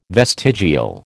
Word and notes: vestigial: American English

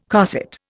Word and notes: cosset: Indian

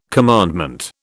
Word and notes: commandment: British English

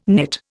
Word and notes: knit: Indian

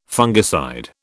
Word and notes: fungicide: British English

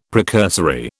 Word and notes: precursory: British English

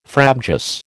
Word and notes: frabjous: American English

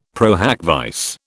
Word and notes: pro hac vice: British English

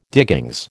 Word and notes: diggings: American English

